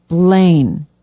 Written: blaine

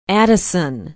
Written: addison

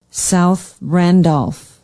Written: south randolph

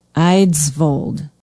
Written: eidsvold

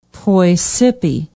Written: poy sippi

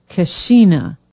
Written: keshena

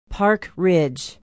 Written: Park Ridge

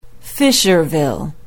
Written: Fisherville

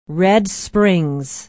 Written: red springs

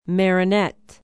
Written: Marinette

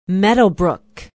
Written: meadowbrook

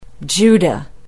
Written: juda

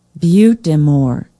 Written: butte des morts

